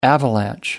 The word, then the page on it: avalanche: American English